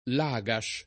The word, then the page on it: Lagash: lingua italiana